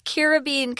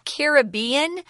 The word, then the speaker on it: Caribbean: It says, on Costa Rica's North Caribbean coast lies Tortuguero National Park. You can also pronounce Caribbean. They're both correct.